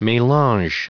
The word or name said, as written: melange